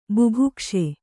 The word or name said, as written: ಬುಭುಕ್ಷೆ